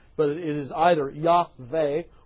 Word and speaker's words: Yahweh: because sometimes it's Yahweh and sometimes it's Jahweh. But remember that there is no such term, there is no such sound as J in the Hebrew language. It's the Hebrew letter Yod, pronounced like a Y.